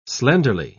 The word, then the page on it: slenderly: British English